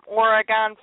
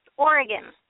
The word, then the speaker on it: Oregon: Oregon. Not Oregon. – Oregon. – Oregon. We spend years trying to teach performers who come to our state to pronounce it correctly.